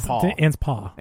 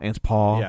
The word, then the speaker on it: Anspaw: People who were kind enough to share the show this week: Librarian Cynthia, Linnea Thunsell, Lane LeVanway, Troy Anspaw. I still feel like I'm saying that wrong, but he hasn't corrected me in a while. – Anspaw. – Anspaw. – Yeah.